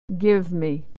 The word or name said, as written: give me